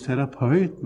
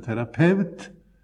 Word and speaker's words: terapeut: Av det er også dannet ordet terapeut, som ikke skal uttales terapeut, men terapeut, hvis det er korrekt, som betyr en som utøver helbredende virksomhet.